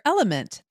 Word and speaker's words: LMNT: Sarah, we're welcoming our sponsor, Element. That's spelled L-M-N-T,